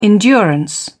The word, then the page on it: endurance: British English